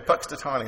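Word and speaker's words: Punxsutawney: And it was somewhat ironic that on Groundhog's Day, we're talking about someone who lived close to Puxtatawny. Did I say it right, Vernon? Okay, Puxtatawny.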